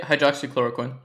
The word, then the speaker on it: hydroxychloroquine: And this brings me on to hydroxychloroquine. Have I said it right? – Yeah, hydroxychloroquine, yeah.